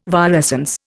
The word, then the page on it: virescence: Indian